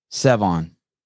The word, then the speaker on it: Sevan: How you say it again? – Sevon. – Sevon. Sevon.